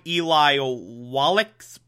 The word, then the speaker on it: Eli Wallach: This time, Eli Wallach's playing him.